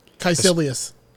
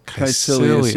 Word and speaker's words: Caecilius: Mads' character, he uses, this is another one of those, how do you say this name? – Caecilius. – Caecilius. Yeah, that's what I would say. I don't know that that's... – No, it is. I've watched it enough times.